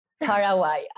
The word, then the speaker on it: Paraguay: Paraguay.